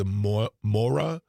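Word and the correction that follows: Māori: tribe? – Maori tribe. Maori.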